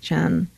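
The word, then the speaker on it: Chien: That's how to pronounce her name. I know I had a question about that last time. It's C-H-I-E-N, Chen.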